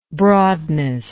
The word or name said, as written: broadness